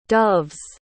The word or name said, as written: doves